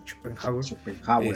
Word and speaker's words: Schopenhauer: Schopenhauer.